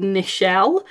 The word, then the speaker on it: Nichelle: I know someone who's called Nichelle, which is Michelle with an N at the front.